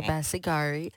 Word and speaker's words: Sebasigari: Did I say it right? – Okay. Sebasigari. – Sebasigari. – Which is spelled Jennifer, J-E-N-N-I-F-E-R.